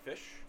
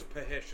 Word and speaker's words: Phish: P-H-I-S-H. – Oh. – I know. Crazy, crazy. – That's how you say it? – I thought it was Pahish until like a week ago.